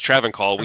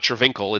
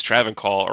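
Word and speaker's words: Travincal: He says Travancol. We have the record of how someone says it, so it's not Travinkle, it's Travancol or whatever, right, as an example.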